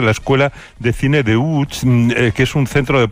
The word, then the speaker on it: Łódź: Aunque se pronuncia Łódź, se escribe Lodz, por si alguien lo busca. – Lodz, sí, Google. Casi no tiene nada que ver la pronunciación.